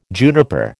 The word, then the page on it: juniper: American English